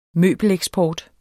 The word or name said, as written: møbeleksport